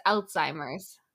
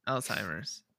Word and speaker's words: Alzheimer's: Everyone always says Alzheimer's when it's Alzheimer's. – Yeah, Alzheimer's. Because it's a Z, not a T. – It's Alzheimer's. – It sounds German. – Alzheimer's. – Weder than Alzheimer's.